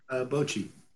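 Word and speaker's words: Bocchi: Now, I did want to talk about you said that you were also watching, is it Bokchi or Bokchi the Rock? Is that how you pronounce it? – Bochi. – Bochi.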